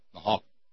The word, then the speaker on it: Nahuatl: Nahuatl. I don't think you pronounce the L. Nahuatl.